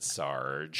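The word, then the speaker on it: Sarg: But they were very kind and told me that you pronounce it Sarge.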